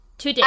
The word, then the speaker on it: Tudyk: Tudyk. – Tudyk.